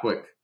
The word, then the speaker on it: QUIC: At the risk of sounding, is QUIC, do you guys pronounce that Quick? Is that Quick? – Yeah, Quick.